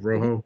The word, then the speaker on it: Rojo: Did I say it right? Rojo? – Yep, perfect.